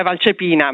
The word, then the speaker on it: Valcepina: Il cognome è pronunciato abbastanza bene, siamo da otto, l'accento è Valcepina, ma va benissimo così.